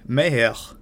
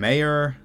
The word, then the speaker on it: Mayer: Kevin Meyer, that's my mint French pronunciation of a man who you might call Kevin Mayer, spelled like John Mayer.